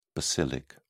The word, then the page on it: basilic: British English